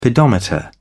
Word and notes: pedometer: British English

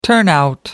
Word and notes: turnout: American English

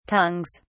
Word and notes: tongues: British English

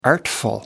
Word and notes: artful: American English

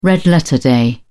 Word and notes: red-letter day: British English

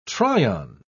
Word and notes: try-on: British English

